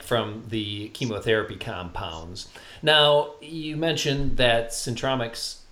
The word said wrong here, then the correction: Synpromix: did I say that right? Uh, it's Synpromix. Synpromix, okay.